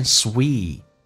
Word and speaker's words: sweet: 我们来看一下 sweet 长元音，嘴角咧到极限 sweet， T 不发音，但是气流要阻塞一下 sweet smile，关键是 L 这个发音跟后面的 at 可以形成个连音，就变成 sweet smile at me sweet smile at me sweet smile at me。